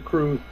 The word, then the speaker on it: crwth: It's spelled C-R-W-T-H. It's a Welsh instrument. – That can't be right. – Comes to us from Wales. C-R-W-T-H. I think it's pronounced the crew.